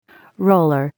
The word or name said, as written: roller